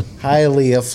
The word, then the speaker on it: Hialeah: Hialeah. – How you doing? – Hialeah, Florida.